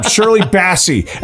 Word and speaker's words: Shirley Bassey: He's going for Shirley Bass because he's the bass player. – Bass. I screwed that up. Shirley Bassey.